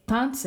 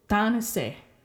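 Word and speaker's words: tanisi: The full word is tanese. Tanese. There's three syllables in there, but nobody ever pronounces them because in rapid speech, you just drop some of these I's.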